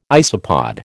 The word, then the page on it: isopod: American English